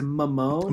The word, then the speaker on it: Mamone: His, his name is Mamone. – Mamone. Like Ramon, like yeah. Ramon, but Mamone.